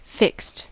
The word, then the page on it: fixed: American English